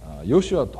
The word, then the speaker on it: ヨシュア: マリアは男の子を産みます、その名をヨシュアと付けなさい。いくら牧師でも聖書を勝手に変えていいのかなんて怒られそうな感じですけれども、実はこのイエスという名前はですね、本来はといいますか、これはギリシャ名でありまして、ヘブル語ではヨシュアというのが正しい読み方であります。